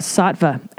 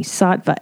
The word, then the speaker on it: Saatva: And that's when I found out about Sattva, S-A-A-T-V-A, mattress company. Sattva, S-A-A-T-V-A. That's how you spell it.